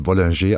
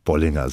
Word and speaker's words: Bollinger: Und, äh Sie haben auch gerade gesagt, natürlich in Frankreich spricht man das Bollinger aus, aber ich habe mich mal kundig gemacht, man darf Bollinger sagen.